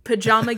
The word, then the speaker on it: pajama: but I've been in the pajama game where they like the director was like, no, like get this Chicago shit out of your mouth, it's pajama.